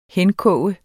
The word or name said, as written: henkoge